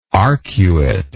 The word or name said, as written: arcuately